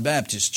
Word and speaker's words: Baptist: They don't pronounce the word B-A-P-T-I-S-T, but in the South, they pronounce it as if the word was B-I-B-D-I-S-T.